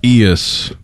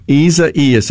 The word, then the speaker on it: Isaias: Isaias. – Isaias. That's right. It's got an extra syllable in there. Gee whiz. On the ceasefire text line. – The emphasis is on the second E sound.